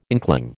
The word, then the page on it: inkling: American English